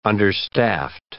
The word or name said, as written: understaffed